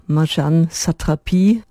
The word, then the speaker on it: Marjane Satrapi: Zunächst allerdings, Sie haben es vielleicht kürzlich in den Nachrichten gehört, Marjane Satrapi oder Marjan Satrapi auf Französisch ausgesprochen, Comiczeichnerin, Filmemacherin, Illustratorin von Kinderbüchern und Malerin, zuletzt wohnhaft in Paris, ist gestorben.